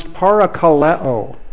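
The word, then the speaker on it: parakaleo (παρακαλέω): The word translated encourage in our New Testament is related, it comes from the Greek word that would be pronounced parakaleo. Parakaleo.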